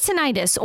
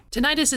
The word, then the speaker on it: tinnitus: What is tinnitus or ringing in the ears? – Tinnitus is the medical term for ringing in the ears. Some people also pronounce it tinnitus. Both are correct, but basically it can refer to any sound that a patient hears in their own ear.